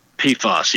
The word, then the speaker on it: PFAS: but the acronym is it PFAS? – PFAS. Yep. – PFAS.